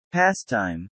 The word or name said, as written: pastime